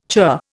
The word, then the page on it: churr: Indian